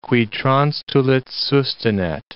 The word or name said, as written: qui transtulit sustinet